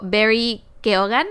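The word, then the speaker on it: Barry Keoghan: Quiero mandarme un saludo a mí, a mi esposo Barry Keogan, así se pronuncia.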